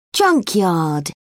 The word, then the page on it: junkyard: British English